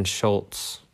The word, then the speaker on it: Schultz: Am I saying that correctly? – He's a Cowboy player. You should be able to know how to say it. It's Dalton Schultz.